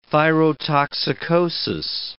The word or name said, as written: thyrotoxicosis